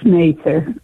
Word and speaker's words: Mather: And It's Mather. That's the way they pronounce Mather.